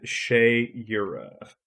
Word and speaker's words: Shayura: And um, Aisha, um, she freezes, uh, Soraya or Shura. – Uh, Shay Yura is how I keep I'm saying it wrong.